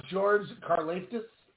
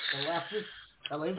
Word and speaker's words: Karlaftis: Can you pronounce it for me? – George Karlaftis. – There you go. – Karlaftis.